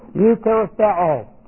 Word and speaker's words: eucharisteo: This is E-U-C-H-A-R-I-S, charis is the word grace, T-E and a long O, eucharisteo.